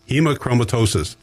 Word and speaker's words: Hemochromatosis: Hemochromatosis.